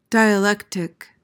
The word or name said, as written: dialectic